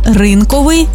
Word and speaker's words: ринковий: Отже, цілодобовий та щодобовий, 100%-овий, ринковий, одноразовий та багаторазовий, наскрізний та вишиваний.